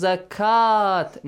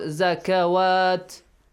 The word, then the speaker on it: zakat: So it will be pronounced as zakat, not zakawat.